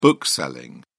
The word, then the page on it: bookselling: British English